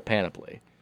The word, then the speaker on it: panoply: Does he Penelope? – I don't think he's got a panoply. – Penelope, Lamar, I can't pronounce the word right.